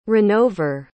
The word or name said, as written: runover